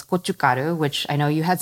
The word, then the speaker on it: gochugaru: It's gochugaru, G. G-O-C-H-U, G-A-R-U, gochugaru.